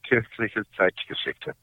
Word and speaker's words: Kirchliche Zeitgeschichte: That one's called Kirchliche Zeitgeschichte.